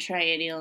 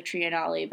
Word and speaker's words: triennale: We say biennial instead of biennale and triennial instead of triennale. But yeah, I guess Aichi Triennale is the, if you were going to say it properly with respect... So please excuse me for my previous times of saying triennial and all of the subsequent times after this that I will.